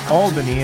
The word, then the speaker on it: Albany: But I really enjoyed Albany, which is where I was staying, which I've been told is not pronounced Albany 100 fucking times. And I said Albany, and I made New Yorkers everywhere cringe. Like, no, it's Albany. You have to say Albany. It's Albany. Like, okay.